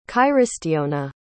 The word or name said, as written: cairistiona